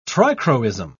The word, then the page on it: trichroism: British English